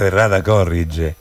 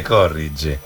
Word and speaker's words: errata corrige: Ho sempre detto errata corrige, mettendo l'accento sulla terzultima sillaba, e invece latinamente sta sulla penultima, credo, no? Sì. Spesso siete pronunciati errata corrige per via di un errore molto comune, no, però mi dicono, insomma, invece che si dice corrige.